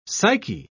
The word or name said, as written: psyche